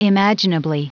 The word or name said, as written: imaginably